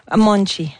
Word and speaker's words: Monte: Oui, Marisa Monte avec son Ok, Monte, on dit Monte? – Monte. – Monte, ça s'écrit M-O-N-T-E pour les intéressés.